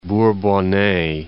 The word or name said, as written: Bourbonnais